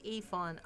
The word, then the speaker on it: Yifan: So that's YIFANWU, and it's pronounced Yifan, unless that Starbucks dude who called me Rakim. I'm not a 90s hip-hop legend. My name's Yifan.